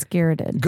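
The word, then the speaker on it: garrote: What's garroted? – Isn't that what that is? – Garrot. It's garrot. – I'm sorry. Yeah. I totally have said that wrong like every time.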